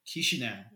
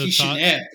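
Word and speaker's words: Chișinău: So he's from the capital of Moldova, Chisinau. Or so Chisinau, if you want to use the alternative pronunciation.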